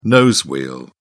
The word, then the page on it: nose wheel: British English